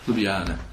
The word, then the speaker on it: Ljubljana: Ljubljana, yeah.